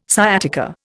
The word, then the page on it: sciatica: Indian